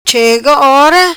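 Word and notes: Ora Chega: patua

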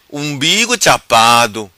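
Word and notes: Umbigo Chapado: Macanese Patuá

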